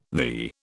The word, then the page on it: thee: British English